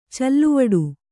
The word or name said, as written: ಚಲವಿಡಿ